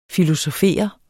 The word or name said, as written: filosofere